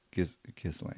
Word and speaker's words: Ghislaine: We actually had looked this up on the internet, how to say this name. – Yeah. – Gislaine? – No, Gislaine. – Oh, She's the daughter of, Ghislaine.